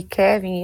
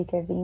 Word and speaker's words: Kevin: É, voltando ali para o MC Kevin, eu até falei Kevin, mas falei errado, é, Kevin, uhum.